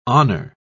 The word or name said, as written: honor